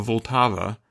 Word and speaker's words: Vltava: I love the Moldau, or as they say in Prague, the Vltava. I think it's how you say it. The Moldau, that's the German word.